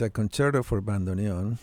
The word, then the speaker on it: bandoneon: Now I'm going to say this wrong. Bandoneon. Is that you said it perfectly well? – Perfect, right. Yes.